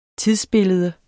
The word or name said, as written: tidsbillede